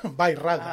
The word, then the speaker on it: Bairrada: who is a Portuguese wine producer from Beirada, right? Did I say that correctly? – Beirada. How do you spell it? B-A-I-R-R-A-D-A. Beirada. – All right. Now, Beirada. – That's it.